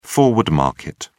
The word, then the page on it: forward market: British English